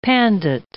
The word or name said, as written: pandit